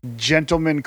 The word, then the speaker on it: Djentleman: Gentleman Chris Adams, but it's spelled DJENT.